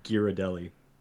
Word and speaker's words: Ghirardelli: Ghirardelli. – Wait, but that's actually pronounced that way. – Because that's how it's supposed to be pronounced.